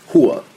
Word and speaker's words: Rua: Well, it's Rua in English, but the R in Portuguese is pronounced with an H, and Shogun is a Brazilian. So it's Hua.